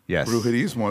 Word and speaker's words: Brujerizmo: Brujerismo? – Yes. – Brujerismo.